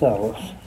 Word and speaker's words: Ellis: Frank and Phyllis Ellis. – How do you spell that? – E-L-L-I-S. – E-L-L-I-S.